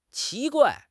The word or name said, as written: qi2 guai4